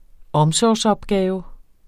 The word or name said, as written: omsorgsopgave